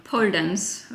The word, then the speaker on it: Pole Dance: Und zwar, ich habe sicher falsch ausgesprochen. Bulldance. Wie, wie, wie spricht man es aus? Pole? – Pole Dance. Also. Pole Dance, okay.